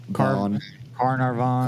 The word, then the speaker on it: Carnarvon: Yeah, I think it's Carvanon. – Carnarvon. – Carvavon. Okay.